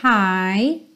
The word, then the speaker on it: high: Lembrando, esse high tem a mesma pronúncia do hi, hello, how are you doing? Ok? High. Então, esse GH, você não precisa se preocupar com ele.